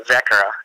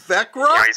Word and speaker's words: Växjö: So yeah, that's everyone butchers that Växjö or whatever they want to call it. So it's Vekra. – Vekra? – You know, Vekra. – Oh my gosh. – It's just based on the double O at the end and the silent A.